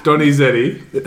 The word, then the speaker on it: Donizetti: Donizetti.